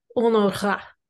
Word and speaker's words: Honorat: It's Honora.